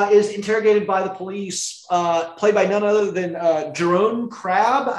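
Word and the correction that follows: Jeroen Krabbé: if I'm saying that correctly. – I think it's Jerome Crabbe.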